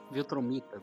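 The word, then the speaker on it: Viltrumita: o Mark é um Viltron. Eu tô falando certo, é Viltron? Voltron? É, Viltraniano. Viltromita, melhor.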